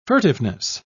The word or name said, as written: furtiveness